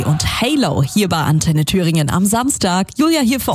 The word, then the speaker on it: Thüringen: Ray Dalton, mega Stimme, vor 2 Jahren auch live bei uns auf der Bühne bei Das Konzert von Antenne Thüringen.